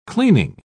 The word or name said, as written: cleaning